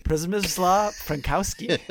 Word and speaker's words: Przemysław Frankowski: Przemysław Frankowski.